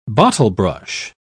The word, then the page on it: bottlebrush: British English